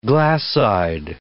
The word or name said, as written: glass-eyed